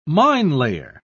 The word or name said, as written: minelayer